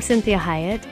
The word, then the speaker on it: Cinthia Hiett: I'm Cinthia Hiett, and again, I'm always thankful that you join me on these Sunday afternoons.